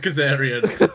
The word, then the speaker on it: Kazarian: Is it actually Kazarian? – It's Kazarian.